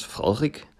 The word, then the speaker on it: Frederik: Wir haben also unseren Kronprinz Frederik, und das ist eine stützende D, wie wir Dänen sagen. Das wird mit einem Stoßlaut ausgesprochen und es klingt dann Frederik und nicht Frederik, so wie Sie es benutzt haben.